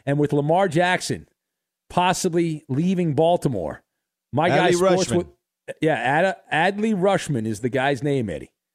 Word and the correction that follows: Rutschman: They said on the highlight it was Rutschman. – Not Rushman.